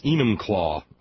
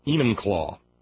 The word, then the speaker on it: Enumclaw: I got Whatcom County right, and it's Enumclaw, I'm now told, by a couple of different sources. Enumclaw.